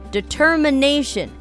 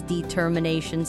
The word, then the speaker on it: determination: Determination. Some people say it as determination.